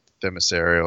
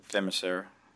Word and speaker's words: Themyscira: It's uh, it's got the Greek reference Themyscira, or I'm pronouncing it wrong. Yeah, no, you're right. Though. Themyscira.